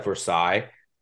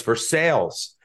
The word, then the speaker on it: Versailles: There's a town called Versailles. It's not Versailles. It's spelled like Versailles, but they pronounce it Versailles. I come down here. I'm like, Versailles? They're like, no, it's Versailles. So I'm like, all right, fine.